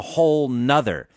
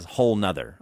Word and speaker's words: whole nother: But the way that Dan pronounces it, he says there's a whole nother, as if to say whole plus another, which is totally grammatically incorrect. However, almost every native speaker I've listened to says whole nother.